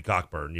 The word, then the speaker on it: Cockburn: It's not like with Kobe Cockburn, you know, like that's, I pronounced it that way because that's how it's pronounced.